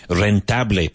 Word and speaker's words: rentable: Rentable, Pablo. – Profitable. – Very good, yeah. The emphasis on the first syllable.